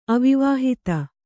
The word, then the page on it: ಅವಿವಾಹಿತ: avivāhita